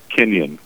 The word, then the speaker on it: Kinyon: Kinyon. K-I-N-Y-O-N.